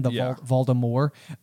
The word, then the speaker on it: Voldemort: The other kind of interesting thing from an audiobook standpoint is at this point in the audiobook, Jim Dale is pronouncing Voldemort in that way where it's a silent T at the end, Voldemort. And as the films are released, they do pronounce the T.